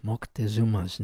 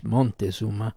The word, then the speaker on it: Moctezuma: I wonder if he took that from Moctezuma's name. Also been, I think, wrongly pronounced Montezuma.